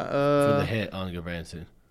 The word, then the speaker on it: Gudbranson: We saw how do you pronounce his name? Gudbranson. – Yeah. Gudbranson with a 26 minute penalty, and he only got a one game suspension.